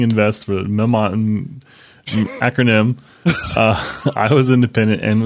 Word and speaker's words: mnemonic: It's not mnemonic. It's actually mnemonic. – Right, mnemonic. The M is silent.